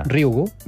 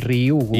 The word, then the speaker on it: Ryugu: Ryugu. És molt fàcil, com una I, Ryugu.